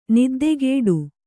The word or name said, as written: ನಿದ್ದಕಟ್ಟು